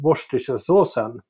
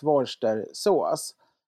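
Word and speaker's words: Worcestershire: För jag har ju alltid sagt Worcestershiresås. Och man kan ju välja om man uttalar kör eller inte. Det kan få falla bort, det är alla eniga om.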